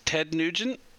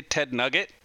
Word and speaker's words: Nugent: Ted Nugent? Is that it? I always want to say Ted Nugget, but of course that's not it.